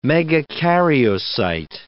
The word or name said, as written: megakaryocyte